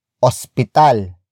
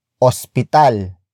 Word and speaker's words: ospital: Hospital. Hospital. Hospital is spelled as O-S-P-I-T-A-L.